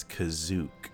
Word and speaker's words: Kashyyyk: And before I get into WoW stuff, I had no idea that in the Star Wars holiday special, they pronounced Kashyyyk as Kazook.